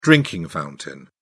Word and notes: drinking fountain: British English

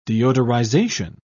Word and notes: deodorization: British English

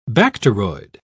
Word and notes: bacteroid: British English

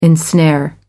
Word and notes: ensnare: American English

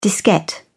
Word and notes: diskette: British English

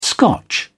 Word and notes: scotch: British English

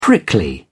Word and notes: prickly: British English

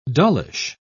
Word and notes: dullish: British English

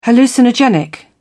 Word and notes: hallucinogenic: British English

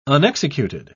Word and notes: unexecuted: British English

